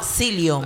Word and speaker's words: psyllium: Silicium. – No, Silium.